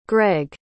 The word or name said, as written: gregg